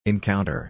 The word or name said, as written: encounter